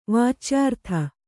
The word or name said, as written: ವಾಚ್ಯಾರ್ಥ